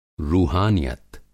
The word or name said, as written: रूहानियत